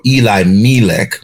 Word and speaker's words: Elimelech: or Eli Melech. When I spoke with an Orthodox Jew, and he said the proper pronunciation is Eli Melech.